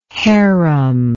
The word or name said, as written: harim